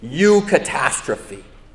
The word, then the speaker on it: Eucatastrophe: And he coined this word, Eucatastrophe. Eucatastrophe. It's on the front of your worship folders to see how it's spelled.